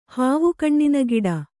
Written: ಹಾವುಕಣ್ಣಿನಗಿಡ

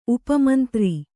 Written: ಉಪಮಂತ್ರಿ

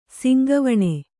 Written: ಸಿಂಗವಣೆ

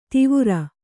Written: ದೇವರ

